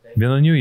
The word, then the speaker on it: Villeneuve: And it's been remade by Denis Villeneuve, however you say his name. Villeneuve, yeah.